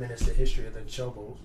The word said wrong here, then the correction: Chozo: I don't know how to pronounce it. – Chozo. – The bird people. – Yeah, the Chozo.